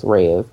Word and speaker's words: reais: It's spelled real, R-E-A-L, but it's Reais.